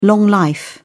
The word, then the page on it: long life: British English